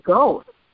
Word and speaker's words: Goethe: but it used to be Goethe, if, you know, if you're pronouncing it the German way, but that's not how Eastsiders, you know, pronounce it.